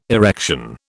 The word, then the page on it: erection: British English